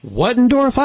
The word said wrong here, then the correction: Bettendorf: Yeah, I think it's spelled Bettendorf.